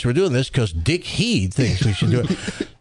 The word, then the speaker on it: Head: Mr. Heed, H-E-A-D, Dick Heed. And I said, he pronounces his last name Heed, so we'll go with that, Dick Heed.